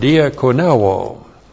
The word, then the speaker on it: diakoneo: Word number one is the verb, diakoneo. Looks like that in the Greek, diakoneu, long O, diakoneo.